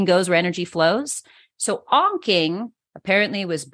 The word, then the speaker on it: Onking: Onking? – Onking. Onking. Yeah, it's spelled O-N-K-I-N-G.